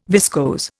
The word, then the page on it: viscose: Indian